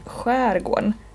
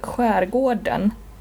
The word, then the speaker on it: skärgården: Men när man pratar om just västkusten eller östkusten i Sverige, då säger man skärgården. Eller skärgården. Samma som alltså uttalet skärgården. Det betyder samma som skärgården, på samma sätt som trädgården är samma som trädgården.